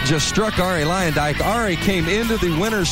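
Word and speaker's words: Luyendyk: Ari Lyondike. Ari Lyondike. Det har blivit väldigt eh, amerikaniserat. Ari Lyondike där.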